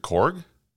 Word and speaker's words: Korg: Is it Korg? – Yeah.